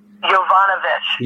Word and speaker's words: Yovanovitch: What is the pronunciation of that ambassador's name again? – Yovanovitch. – Yeah, and she... – Yovanovich.